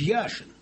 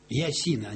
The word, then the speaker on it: Яшин: Я же помню, какой был популярный Лев Иванович Яшин. Я вот приехал в Бирму когда-то с «Зенитом» в 66-м году. И там две фамилии называли. Гагарин и Яшин. Ясина они называли, ша они не произносят.